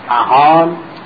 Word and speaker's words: اصفهان: مثلا کلمه اصفهان این معربه، در از سپاهان بوده و معرب شده، سینش قلب به ساد شده و پیوم چون در عربی نیست به فه تلفظ میشه.